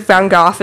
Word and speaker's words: Van Gogh: That's how everyone else pronounce it. – Really? – The French pronounce it Van Gogh as well.